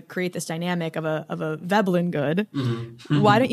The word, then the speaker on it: Veblen: I'm not going to pronounce it probably correctly, but Veblen or Vablin goods? – Veblen, I think.